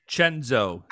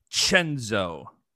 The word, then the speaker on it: Cenzo: Chenzo, C-E-N-Z-O, promo code Chenzo. Hey, silent I, H. – No H.